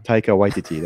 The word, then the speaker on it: Taika Waititi: Your Robert Downey Jr. Iron Man, your Waika Taititi, or Waika. Why am I not saying his name right? – We're an hour or two of the podcast, man. – Taika Waititi, that's his name.